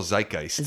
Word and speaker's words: zeitgeist: Cultural zeitgeist.